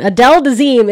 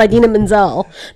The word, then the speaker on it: Idina Menzel: Adele Dazeem is what John Travolta's dumbass called Idina Menzel that one time because he didn't know how to say her name because he's stupid.